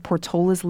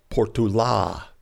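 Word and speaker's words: Portolá: He had an accent at the end of his name, so the name should be pronounced Portola." – Finally, how he would have pronounced it, with the accent and stress on the A. The long-lost accent, Portola.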